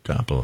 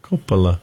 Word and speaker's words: Coppola: So if you're Italian, it's Coppola. But here in America, it's Coppola.